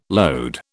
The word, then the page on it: lode: British English